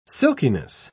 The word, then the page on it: silkiness: British English